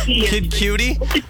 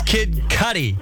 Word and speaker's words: Kid Cudi: I don't know if it's really that old, but do you have A Day and Night by Kid Cudi? – Oh, Kid Cudi? – I don't know how to say it. – It's Kid Cudi.